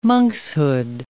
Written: monkshood

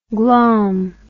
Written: glaum

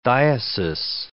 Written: diocese

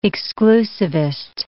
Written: exclusivist

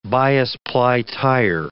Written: bias-ply tire

